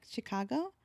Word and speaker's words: Chicago: And I was like, Chicago? My family lives in Chicago. – Oh, so you pronounce it with an S, S H I, she. – Like she. she. Yeah. Yeah. Like Chi Town.